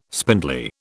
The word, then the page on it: spindly: British English